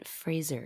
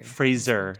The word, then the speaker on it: Fraser: I think his name is pronounced Brendan Fraser. – Fraser. Sorry. Fraser. I trust you.